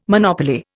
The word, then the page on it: monopoly: Indian